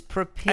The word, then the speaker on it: propitiate: John, I stopped listening because I was closing some tabs on my computer there, and it turns out you were right about the correct pronunciation of propitiate. I guess I've always said right. – Propitiate. – It's the internet. – But it's propitiate.